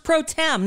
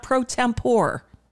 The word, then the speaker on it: pro tem: It's pronounced pro tem, not pro tempore.